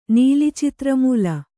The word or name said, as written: ನೀಲಿಚಿತ್ರಮೂಲ